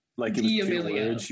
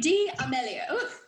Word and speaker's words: D'Amelio: She was saying D'Amelio. There was a period there. – Charlie D'Amelio. – Yeah. But it's D'Amelio, and I see that now.